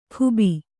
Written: ಖೂಬಿ

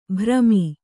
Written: ಭ್ರಮಿ

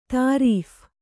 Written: ತರ್ಫಿ

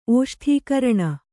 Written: ಓಷ್ಠೀಕರಣ

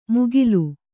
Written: ಮುಗಿಲು

